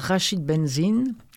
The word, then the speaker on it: Rachid Benzine: Livre écrit et lu par Rachid Benzine. Apparemment, c'est ainsi qu'il faut prononcer son nom.